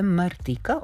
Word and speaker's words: Martika: Vidiš koju je Martika ili Martika sad, i tu je pitanje kako pravilno da izgovorimo. Gledala sam intervjue, ona samu sebe zove Martika, ok.